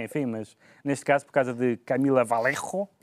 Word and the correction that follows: Vallejo: Vallejo. – Vallejo, mas com uma é pronúncia.